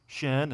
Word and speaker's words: shin: So in Hebrew, if the dot's on the very beginning, which is right to left, it's shin. If it's on the end, it's sin.